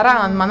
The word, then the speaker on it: Ranma: Ranma, non l'avevi visto Ranma? – No.